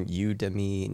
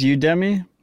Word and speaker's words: Udemy: I think it's Udemy. It's a horrible name. – Udemy. – It's like Academy, but you. Is that the idea?